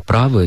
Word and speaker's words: правы: Здесь очень хорошо можно ориентироваться на небезызвестную песню Аллы Борисовны Пугачевой «Ты и я, мы оба правы», и это правильное ударение.